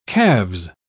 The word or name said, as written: calves